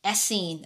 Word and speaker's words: Essene: Is it Essen? You know what? Google's going to help me out on this one. Okay, so, and some of you probably think, knowing like, oh, this is how you pronounce it. Let me hear it right now. – Essene. – Okay, thank you, Google. Essene, okay?